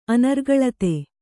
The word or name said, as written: ಅನರ್ಗಳತೆ